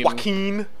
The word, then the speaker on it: Joaquin: Joaquin.